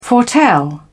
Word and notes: foretell: British English

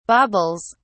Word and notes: baubles: British English

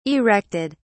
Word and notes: erected: British English

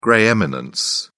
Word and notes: grey eminence: British English

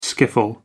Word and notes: skiffle: American English

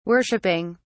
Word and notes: worshiping: British English

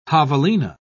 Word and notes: javelina: British English

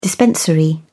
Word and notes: dispensary: British English

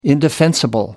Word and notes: indefensible: American English

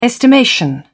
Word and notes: estimation: British English

